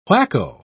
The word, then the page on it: whacko: British English